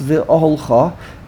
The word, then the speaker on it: V'Oholcha: And here it's a hard word to pronounce, V'Oholcha, and notice there's no emphasis on the Chaf, emphasis on the Vase and the Aleph.